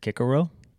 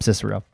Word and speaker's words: Cicero: An ancient world history professor who insisted that you would pronounce it Kikero.